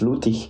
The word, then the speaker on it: Lüttich: And the German version is Lüttich.